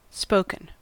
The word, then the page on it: spoken: American English